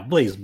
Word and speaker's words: BlazBlue: BlazBlue, is that how you pronounce it? – Yeah, BlazBlue.